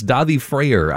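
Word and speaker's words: Daði Freyr: It's Davi Freyr, I believe this is how this person's name is pronounced.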